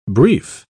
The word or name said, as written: brief